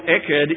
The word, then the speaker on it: Echad: The word there in the Hebrew is, and I'll spell it for you, E-C-H-A-D. Echad. E-C-H-A-D.